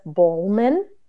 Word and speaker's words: Bohlmann: Today, I have Elizabeth Bowman.